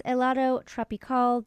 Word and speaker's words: Helado Tropical: At the top of the set, Dehate Kair. I had it better pronounced in my head, and then I messed it up. But that's Helado Tropical.